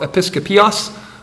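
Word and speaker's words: Episcopius: And they were led by a man named Simon Episcopus, is probably how you'll hear it said, Episcopus. It looks like it's spelled Episcopios. I don't know how it's technically pronounced. If you hear either one, it's the same guy.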